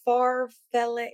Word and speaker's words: farfalle: Well, they're like the farfalle. That's what they're called. Like far. How do you pronounce it? Yeah, they're both. Yeah, but that's not what it's called. – Farfalle? Yeah. Okay.